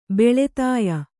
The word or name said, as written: ಬಲತಾಯಿ